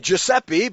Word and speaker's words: Giuseppe: It does have a sound like the English J, but it is spelt with a G followed by an I or an E, as in Giuseppe.